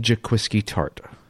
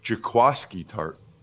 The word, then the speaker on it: Jaquiski Tartt: It's Jaquiski Tartt. I mean, if you spell it and asked 100 people, they would say, 99 would say it's Jaquiski Tart. But evidently it's pronounced Jaquiski Tart.